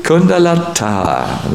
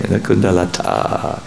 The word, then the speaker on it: Kundalata: And this Subhadra is married to Kundalata, long A, a female name, Kundalata. So,